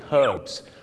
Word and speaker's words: herbs: ชาวอังกฤษก็จะออกเสียง herbs ตัว h หอนหูชัดเจนมากแต่ชาวอเมริกาเยอะมากครับออกเสียงว่า herbs ไม่มีตัวหอนหูกลายเป็นออ่างสมุนไพร herbs กับ herbs